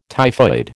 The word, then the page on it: typhoid: American English